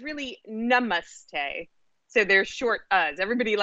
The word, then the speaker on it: namaste: So what does namaste mean, and did I pronounce it correctly? – Well, sort of. They should really be. So in Sanskrit, there's a difference between a short and a long ah. Uh-huh.